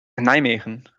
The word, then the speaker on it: Nijmegen: Das ist in Nijmegen, habe ich das richtig ausgesprochen, in Holland? – Nijmegen. Oder Nijmegen auf Deutsch. In Deutschland sagt man, glaube ich, eigentlich Nijmegen.